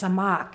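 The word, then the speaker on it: samak (סָמַךְ): The word stayed in the Hebrew is spelled C-A-M-A-K, but it's pronounced samak, and it means that which is upheld, that which is unmoved, that which is firm.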